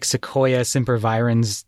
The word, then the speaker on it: Sequoia sempervirens: You know, they've got like Sequoia sempervirens tattooed on them somewhere.